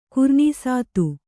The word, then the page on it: ಕುರ್ನೀಸಾತು: kannada